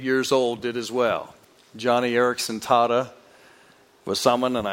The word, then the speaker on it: Joni: It looks like Joni when you see her name, but she pronounces it Johnny.